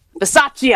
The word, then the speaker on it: Bisaccia: Bisaccia.